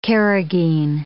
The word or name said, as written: carragheen